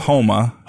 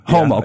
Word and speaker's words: Houma: West of Houma. – Houma. Yeah. Okay. Yeah. Is that the Cajun way to say it? – No. That's just how it's said.